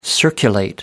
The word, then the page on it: circulate: American English